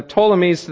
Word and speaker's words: Ptolemies: The Seleucid kingdom in the north, I'm probably saying that wrong, and the Ptolemies to the south. There's a P, but I think it's silent.